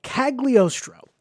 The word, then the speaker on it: Cagliostro: The latter of which, the TMS, is who he directed his feature film debut, which he directed Lupin the Third, The Castle of Caligostro. Cagliostro. I'm going to say that right. That's a weird one.